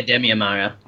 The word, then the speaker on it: Damian Maia: Yeah, Damian Maia.